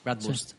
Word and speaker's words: Bratwurst: Bratwurst. – Eso lo hemos pronunciado bien, ¿no? – Sí.